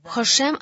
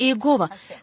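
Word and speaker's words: Хашем: Это непроизносимое имя Бога, которое кто-то догадался произнести как Иегова. Конечно же, оно произносится не так. И евреи говорят «Хошем».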